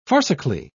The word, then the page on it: farcically: British English